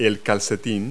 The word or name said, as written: sock